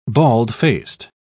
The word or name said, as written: bald-faced